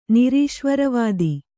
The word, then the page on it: ನಿರೀಶ್ವರವಾದಿ: nirīśvara vādi